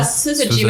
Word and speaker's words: Syzygy: Ja, Syzygy, mit einem harten S. Es wird Englisch ausgesprochen und Syzygy, okay.